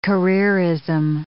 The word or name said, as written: careerism